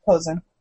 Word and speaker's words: Posehn: And then Mike has said he got his Deadpool issue signed by Brian Potion. Posehn. Posehn, that's how you say it. I always messed up.